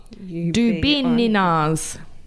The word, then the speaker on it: Dubinina's: Dubinina's. So it's like banana. Dubinina's. Dubininas. – Dubininas, yes. Yes.